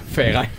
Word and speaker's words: Verein: Pues aquí en este punto es donde sale el primer término en alemán, Verein. Lo he dicho bien. – Verein. – Verein. – Exactamente.